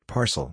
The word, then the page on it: parcel: ar